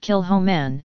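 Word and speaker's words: Kilchoman: Kilcoman, pronounced Kilhoman, is the baby of the group.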